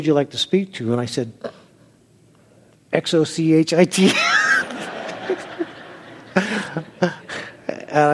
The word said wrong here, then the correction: Xochitl: They said, "It's Soshi," and I said, "Oh, I would have never got that out of that spelling."